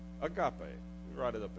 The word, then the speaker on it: agape: It has an A sound. And it's pronounced agape.